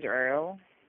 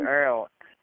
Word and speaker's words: Earl: My name is Earl. – Is your name David? – No, David? – My name is Earl. – Frank? – My name is Earl. It's spelled E-A-R-L.